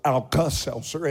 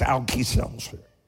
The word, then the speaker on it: Alka-Seltzer: Because when you're from Eastern Kentucky, any word that ends with A, you pronounce with an E. So it's not Alka-Seltzer, it's Alki-Seltzer.